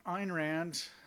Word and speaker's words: Ayn Rand: I know, and I always missay her name, Ryan is one of your, was an influence, but who are some of the other philosophers that you liked or appreciated? – So Ayn Rand,